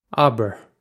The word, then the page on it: abar: Irish